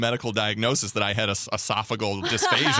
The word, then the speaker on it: esophageal: And I guess it manifests itself in I had a esophageal problems, which I was incorrectly pronouncing for a long time. – Esophageal. – I always thought it was esophageal. – Yeah. We were wrong.